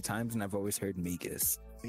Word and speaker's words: Magus: Is that how it's pronounced? Magus? – Yeah, Magus. – Oh, I've been saying Magus.